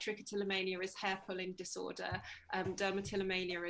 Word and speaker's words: trichotillomania: So you've done, you know, some really interesting articles about eczema, herpeticum, and then also trichotillomania. I can't even pronounce it. – Yeah, it's the longest tongue twister of a word. So trichotillomania.